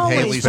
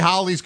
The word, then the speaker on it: Halley: You're going to say Halley's Comet? – No. – Yeah, he is. He's pretentious. He is. Of course he is. – I'm not going to say it's a comet that rhymes with rallies.